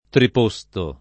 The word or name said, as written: triposto